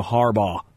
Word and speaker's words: Harbaugh: Jim H., getting the I sound like I'm saying Harbaugh like Haribo, like toys, you know.